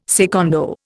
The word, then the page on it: secondo: Indian